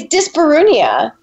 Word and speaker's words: dyspareunia: So dyspareunia, am I pronouncing that correctly? – It's kind of an ugly word, to be honest. It's dyspareunia.